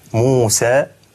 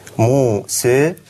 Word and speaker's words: موسى: فمثلا عندنا كلمة موسى، في بين القراءات خلاف في قراءة الألف، في كيفية قراءة الألف، فهناك الفتح موسى اسمه الفتح، هناك التقليل أو الإمالة الصغرى موسى، وهناك الإمالة الكبرى موسى.